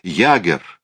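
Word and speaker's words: Jaeger: «Ягер» — так вроде это произносится.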